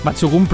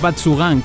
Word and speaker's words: Batsugun: Batsugun pronunciado así, no digáis Batsugan como nosotros, que lo hemos descubierto hace poco.